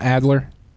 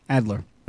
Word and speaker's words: Adler: Robert Alder, did I say his name right? Father of the remote control. – Are you sure it's not Adler? – That's what I meant to say. Adler.